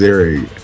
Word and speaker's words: Ziering: Ian Ziering. – Ziering. It's pronounced Ziering.